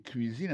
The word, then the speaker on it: cuisine: Autant de cuisine là-dedans, c'est comme ça qu'on dit cuisine, on prononce cuisine en anglais. C-U-I-S-I-N-E. C'est cuisine.